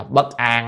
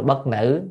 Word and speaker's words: Bất An: Thì có chủ thần tên là Bất An á, hôm bữa nhìn sao không thấy cái bộ miên ở trên trên đọc là bất nữ, nhưng thật ra là bất an.